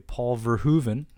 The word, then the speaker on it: Verhoeven: So this would be a Paul Verhoeven. I am saying that correctly. It's been confirmed from an actual Dutch person.